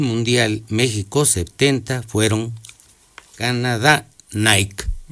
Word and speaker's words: Nike: Es Nike, porque es la diosa griega del deporte.